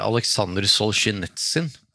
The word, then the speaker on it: Aleksandr Solzjenitsyn: Ja, det er, i, i, årets roman så er det Aleksandr Solzjenitsyn. Jeg greide å uttale det riktig. Mm, ja, det var ikke verst.